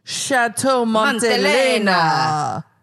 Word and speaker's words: Montelena: We went to this place called Chateau Montelena. You have to say it like that.